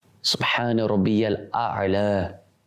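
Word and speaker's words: Subhana rabbiyal a'la (سبحان ربي الأعلى): Subhana rabbiyal a'la. That is the correct pronunciation.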